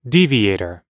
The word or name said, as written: deviator